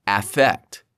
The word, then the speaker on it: affect: For example, most native English speakers use a U sound, like in the word cup, for the first syllable of both affect and effect. Alternatively, you can use an A sound, like in the word cat, for affect, and an E sound, like in the word pet, for effect.